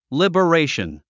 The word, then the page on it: liberation: lìbəréiʃən; リバレイション